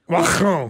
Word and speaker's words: Macron: You so always say, I hate it when reporters say Henri or Macron. – Macron. – That's how you pronounce it.